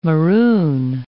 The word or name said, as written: maroon